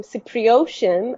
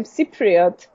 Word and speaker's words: Cypriot: Or as I've been asked if I'm Cypriotian, I'm not Cypriotian, I am Cypriot.